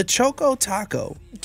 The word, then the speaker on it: Choco Taco: In this news according to Adrian, we have to announce that Klondike has announced the Choco Taco.